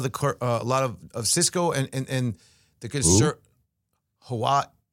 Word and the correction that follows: Huawei: how do you pronounce that? Huawei? – Huawei, yeah. Yeah, no, Huawei's the big boy.